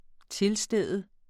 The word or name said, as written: tilstede